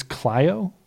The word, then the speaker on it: Clio: Jaquanda Johnson takes to Facebook saying her stepdad's truck in, I think it's Clio, Michigan is how it's pronounced.